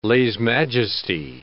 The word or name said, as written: lese majesty